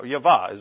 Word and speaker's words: Yahweh: Yahweh is really the pronunciation.